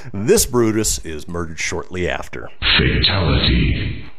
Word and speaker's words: Brutus: Anthony fails to capture Mutina and Decimus Brutimus. Brut, Brutus. God, I did butcher it. Decimus Brutus.